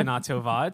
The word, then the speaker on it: Shana Tova: Shana Tova.